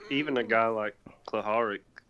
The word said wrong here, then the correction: Solarik: or how do you say his name? – Solarik.